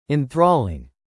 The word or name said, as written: enthralling